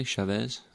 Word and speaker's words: Chavez: Chavez? – Mm-hmm.